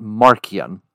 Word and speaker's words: Marcion: Not Martian as in an alien, but some will pronounce it Marcion, but you have Marcion.